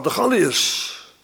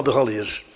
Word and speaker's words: Galiërs: Nou ja, onder de Galiërs. Zo spreek je dat uit officieel. Ik heb dat nagekeken in de klassieke boeken. De Galiërs.